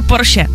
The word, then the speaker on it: Porsche: A pak tady ještě Porsche, jo, to my Češi říkáme skoro ze všech jako národů jediný správně. Všichni říkají Porsche špatně, ale je to opravdu Porsche, takže tady si můžeme jako poklepat na rameno, že to říkáme dobře.